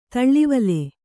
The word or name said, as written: ತಳ್ಳಿವಲೆ